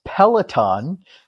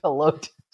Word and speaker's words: Peloton: I'm going to take just a hot second to go ahead and correct your pronunciation, because it's Peloton. – Peloton? – No. Peloton.